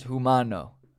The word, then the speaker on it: humano: Oh no, it's humano. Humano, humano. Yeah, humano. We, no pronunciation of the H, but humano.